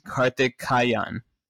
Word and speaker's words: Karthikeyan: There's only been two: Narain Karthikeyan.